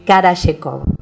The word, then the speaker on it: Karayekov: Mi apellido es Karayekov. Y se escribe así. K-A-R-A-Y-E-K-O-V corta.